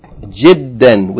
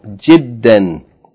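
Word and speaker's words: جدًّا: The last point, number 5, جِدًّا, with شَدَّ on the del, جِدًّا, means very.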